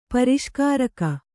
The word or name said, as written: ಪರಿಷ್ಕಾರಕ